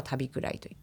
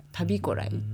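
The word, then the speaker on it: 旅来: これは私のひいひいおじいさん、高祖父が生まれ育った地名で、中川郡豊頃町にあるんですけれども、地元の人は旅くらいと言ったりしていますが、旅こらいと読む、旅が来るって書いて旅こらいと読む場所です。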